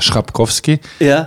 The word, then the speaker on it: Chrapkowski: Schrapkowski. Ja, in der Halle Aussprache ist ein bisschen anders und äh, ich verstehe das für Deutsch, das ist äh, ein schwieriger äh, Name und ja, das. Also CH und ein R ist wirklich ist das Schwerste, was es ja überhaupt gibt, oder?